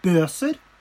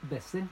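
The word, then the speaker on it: Boeser: Jeg trada til meg eh, Brook Bøser. Var det riktig uttalt? Besser, tror jeg det uttales.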